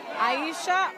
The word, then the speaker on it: Ayisha: Aisha Ogilvy. You want me to spell it? – Sure. – A-Y-I-S-H-A.